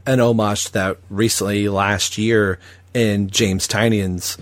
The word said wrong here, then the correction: Tynion: Tynion. – Said it wrong.